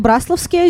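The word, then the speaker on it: Браславские: А, я была удивлена, когда узнала, что правильно Брасловские озера, ну, ударение вот так вот.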